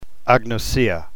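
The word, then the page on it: agnosia: New Testament Greek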